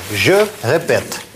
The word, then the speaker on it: répète: Смотрите, у нас окончание вот это uh-huh. не произносится в настоящее время. Je répète. – Je répète.